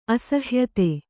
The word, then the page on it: ಅಸಹ್ಯತೆ: kannada